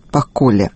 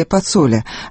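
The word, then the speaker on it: Pacolli: Им стал 59-летний Беджет Паколе, швейцарский бизнесмен, албанец из Приштины. Правильное произношение его фамилии – Пацоле, однако в России было принято иначе.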